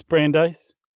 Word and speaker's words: Brandeis: It's Brandeis. – Okay. That sounds right. – Like light ice, but a little different.